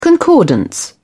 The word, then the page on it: concordance: British English